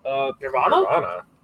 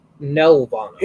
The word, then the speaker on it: Nelvana: Nirvana? – Nelvana.